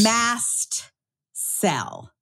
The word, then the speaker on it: mast cell: If you are a listen to podcast and Googler like I am, because I like to multitask, you should know the word that Matt is saying is mast cell, M-A-S-T, which is, see, it sounds like mast cell, so it's hard to Google.